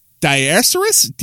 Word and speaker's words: diaeresis: In the past, the mark, which is properly known as and I'm going to pronounce it wrong, I apologize, diaserus, D-I-A-E-R-E-S-I-S.